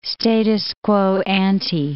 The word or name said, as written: status quo ante